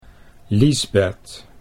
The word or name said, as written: Liesbeth